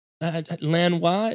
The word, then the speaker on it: Lanois: Like I would argue that Lanois, is that how you pronounce his name? – Yeah.